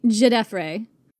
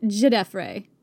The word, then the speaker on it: Djedefre: I really struggle saying it because it's not the way it's spelt, and that really throws me. – Djedefre. – There's a D at the front. – A silent D, correct.